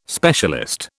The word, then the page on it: specialist: British English